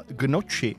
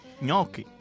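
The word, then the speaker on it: gnocchi: A taky Marťa, ahojda, tak mě tahá za uši, když někdo řekne, že by si dal gnoči, správně je ňoky.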